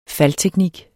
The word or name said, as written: faldteknik